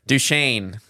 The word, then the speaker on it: Duchesne: Well, the one you've been calling it Dutchess County. Dutchess. – It's Douche? – Duchesne? Yeah, Duchesne County.